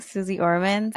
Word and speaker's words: Suze Orman: That about, is it Suze Orman? – Oh yeah, Suze Orman. – Did I say her name right? Suze Orman. I guess she's like the original. – Yes.